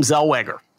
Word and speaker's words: Zellweger: Allison looks like a young Renee Zegwaller. – Oh, interesting. I've never gotten that before. – I think she pronounces her name Zellweger, for whatever that's worth.